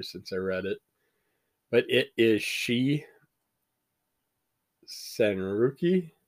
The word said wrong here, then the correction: Senryaku: I believe it's pronounced Senryaku. She, Senryaku.